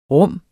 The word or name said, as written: rum